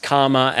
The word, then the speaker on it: Calma: I came across Calma, spelt C A L M A, but as she will always tell you, pronounced karma as in good or bad karma